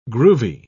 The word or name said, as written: groovy